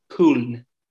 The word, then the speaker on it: Köln: I think we Americans pronounce it Cologne, but it's like Köln.